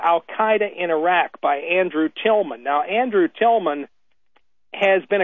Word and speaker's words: Tilghman: But what Mr. Tillman, whose name is spelled T-I-L-G-H-M-A-N,